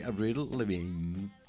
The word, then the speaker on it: Avril Lavigne: Complicated di Avril Lavigne. L'ho detto bene? – L'hai detto, piace? Ci è piaciuto come l'hai detto. – Ecco, sono la seconda che hai detto.